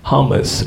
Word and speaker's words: hummus: I was at a friend's house the other day and she was having hummus, and this is how we pronounce it. Hummus.